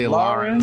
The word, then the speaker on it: Lahren: How do you say her last name? Lahren. – I say Lahren. Lahren. That's what it sounds like, the H there.